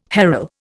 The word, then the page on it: harl: Indian